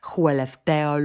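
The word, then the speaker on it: Skellefteå: że się tak specyficznie wymawia Huelefteo, Luleo, Meo, Piteo. To jest takie A z kółeczkiem na górze i ono właśnie tak się wymawia O.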